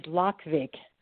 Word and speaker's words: Lokvig: First of all, my last name is Lokvig, but it's a pretty unusual name, so I'll spell it for you. L-O-K.